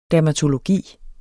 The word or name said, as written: dermatologi